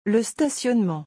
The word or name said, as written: le stationnement